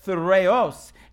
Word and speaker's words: thyreos: Now, this shield, let me tell you, the, the Greek word for this shield is T H Y R E O S, and it's pronounced Thureos, and it is defined as a shield, a large and oblong, oblong four cornered shield protecting every part of the soldier.